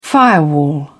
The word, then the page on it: firewall: British English